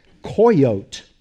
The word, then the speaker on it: coyotl: And in the Nahuatl language, it would be spelled C-O-Y-O-T-L, but the L is silent, mm-hmm. and so the pronunciation is coyote.